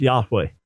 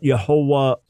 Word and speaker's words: Yahweh: So to catch up those just tuning in now, we best understand the pronunciation of God's name as revealed to Moses as Yahweh, not Yehovah or Jehovah, although we're not dogmatic about that and we don't divide over that.